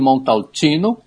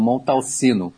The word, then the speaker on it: Montalcino: Brunello di Montalcino, e não Montalcino, tá? Montalcino.